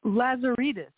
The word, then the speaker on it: Lazaridis: Lazaridis.